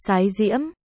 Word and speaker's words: Thái Diễm: 7, Thái Diễm, cũng đọc là Sái Diễm, tự Chiêu Cơ, nhưng sau trùng húy với Tư Mã Chiêu nên người đời sau đổi thành Văn Cơ.